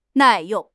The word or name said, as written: 耐用